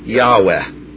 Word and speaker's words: Yahweh: Okay, now, whenever you see this, Lord, all capitals, Lord, all capitals, that is what our version usually translates as Jehovah, or probably ought to be pronounced Yahweh.